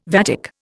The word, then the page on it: vatic: Indian